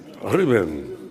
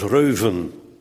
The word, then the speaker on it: Ruben: Ruben. Iemand anders heeft uitgevonden dat je het moet uitspreken als Reuven. Maar ik ken weinig mensen die de eu kunnen uitspreken, behalve dan in Denemarken.